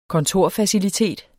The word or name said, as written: kontorfacilitet